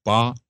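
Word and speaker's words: ba (八): So the number eight is pronounced ba in China, which I guess rhymes with the term fa, which is short for fa te.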